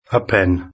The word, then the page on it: a pen: British English